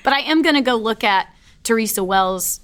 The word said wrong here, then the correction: Wills: The Mather Days. – Wills. – Now I wrote that and then said it wrong.